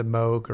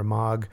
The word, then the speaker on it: Moog: He did it all on this synthesizer, depending on how you want to pronounce it. It's a Moog or a Moog,